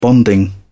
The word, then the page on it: bonding: British English